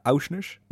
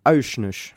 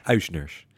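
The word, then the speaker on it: Aursnes: Uh, Uisners. – Uisners. Ja. Dus die AU spreek je uit als ui.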